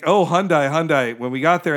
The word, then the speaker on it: Hyundai: When they go to take the thing off Kearney's car, the new H, it's a Hyundai, which I learned is a Hyundai-like Sunday is how it is pronounced, because I'd always said Hyundai as well,